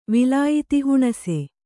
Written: ವಿಲಾಯಿತಿಹುಣಸೆ

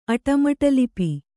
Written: ಅಟಮಟಲಿಪಿ

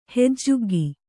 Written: ಹೆಜ್ಜುಗ್ಗಿ

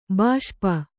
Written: ಬಿಷಪ್ಪು

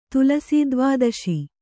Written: ತುಲಸೀದ್ವಾದಶಿ

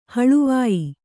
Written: ಹಳುವಾಯಿ

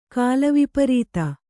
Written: ಕಾಲವಿಪರೀತ

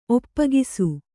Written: ಒಪ್ಪಗಿಸು

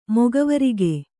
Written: ಮೊಗವರಿಕೆ

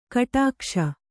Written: ಘಟಾಕಾಶ

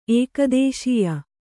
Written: ಏಕದೇಶೀಯ

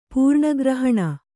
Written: ಪೂರ್ಣಗ್ರಹಣ

